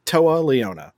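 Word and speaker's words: Toa Liona: Then we had Hangman Adam Page versus Taya Leone. And you know what? I'm officially going to say it correctly. Toa Leona.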